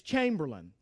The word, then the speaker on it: Chamberlain: By the way, I mispronounced his name. It's Chamberlain.